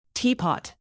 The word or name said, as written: teapot